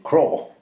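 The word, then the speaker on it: Krogh: Där har vi för övrigt en till som jag misstänker är ett Silent G. Craw.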